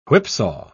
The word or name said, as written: whipsaw